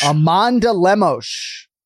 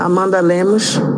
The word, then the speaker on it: Amanda Lemos: Amanda Lemos. Let's hear it. – Mm-hmm. Amanda Lemos.